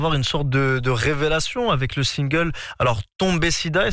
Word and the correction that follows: Tombé Siba: est-ce que je le prononce bien? – Tombé Siba, qui arrive en 2020 et qui cumule plusieurs millions de vues sur les plateformes de stream.